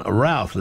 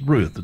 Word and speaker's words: Routh: John Williams and the theme from Superman, and US actor Brandon Routh is 47 today. Maybe it's pronounced Ruth. It's spelled R-O-U-T-H.